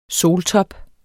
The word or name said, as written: soltop